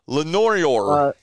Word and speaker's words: Lenoir: Lenoir County? – Lenoir, yeah.